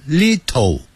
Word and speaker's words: little: 一点点的 ,L-I-T-T-L-E, 好多人都发错了。他念成 little, 不是 little。你看刚才打数 ,it, 二三四 ,it, little, 那个 to 的音是介乎 to 跟 do 之间的含混音。